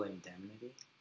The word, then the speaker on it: Indemnity: Oh, so the movie's Double Indemnity. Did I say it right? – Double Indemnity? – Indemnity.